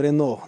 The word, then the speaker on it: Renault: когда читаем Renault, а, произносим Рено, да, или Peugeot, произносим Пежо, Так вот, как французы сегодня говорят Пари понятно, а, а мы говорим Париж.